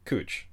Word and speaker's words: Couch: As far as like local nomenclature is concerned, this is pronounced Cooch. – Yeah, there's a street in Portland that is spelled C-O-U-C-H, the way you would spell another name for a sofa.